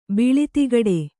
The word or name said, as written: ಬಿಳಿತಿಗಡೆ